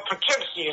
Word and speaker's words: Poughkeepsie: Yeah, at Poskippi, New York, in the Mid-Hudson. – Oh, you mean Poughkeepsie is pronounced.